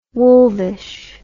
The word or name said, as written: wolvish